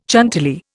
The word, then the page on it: Chantilly: Indian